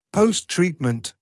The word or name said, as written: post-treatment